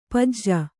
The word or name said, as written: ಪಜ್ಜ